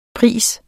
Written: pris

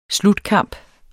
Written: SLUTKAMPE

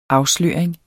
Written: afsløring

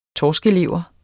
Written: torskelever

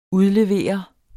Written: UDLEVEREDES

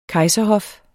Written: kejserhof

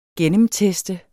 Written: gennemteste

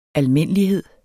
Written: ALMINDELIGHEDS

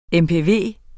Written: mpv

